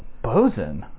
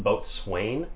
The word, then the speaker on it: boatswain: It looks like it's pronounced boatswain. You might have seen that word. – Yeah, I have. – It's pronounced bosun. I'm pretty sure it was made by the same guy who decided how baloney and colonel were pronounced.